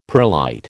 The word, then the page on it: perlite: American English